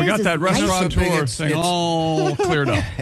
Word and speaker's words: restaurateur: I pronounce it restaurant in my head, and it's that gives you the T-A-U there before you go. – Oh, okay. – I know that the most mispronounced is restaurateur. – Mm-hmm. – There's no N. It's not restaurateur.